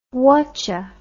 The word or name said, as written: whatcha